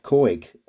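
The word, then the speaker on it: Couhig: I wanted to kind of start with the takeover of the club by the American businessman Rob Coig. I think I've pronounced that right. Have I pronounced that right? – Yeah, spot on.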